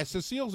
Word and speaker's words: Cecil: We'll take this in a healthy direction because actually with Cecile the Lion, there's a macro issue there. – Isn't it Cecil? – Is it Cecil? – Yeah, Cecile's a girl's name. It was a male lion.